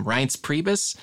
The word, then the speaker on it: Reince Priebus: And then when I never know how to pronounce his name, Reince Priebus. – Yeah. I think you just got it.